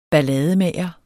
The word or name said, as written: ballademager